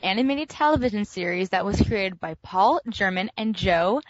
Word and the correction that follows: Germain: Paul Germain.